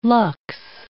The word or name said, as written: lux